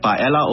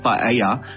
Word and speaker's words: paella: So paella is 'n tradisionele Spaanse dis en die Spanjaarde spreek nie die dubbel al uit soos ons altyd doen nie, maar die Engelse doen wel.